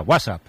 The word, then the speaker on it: WhatsApp: No, peor aún, con acento en la primera, WhatsApp, ¿no? – WhatsApp.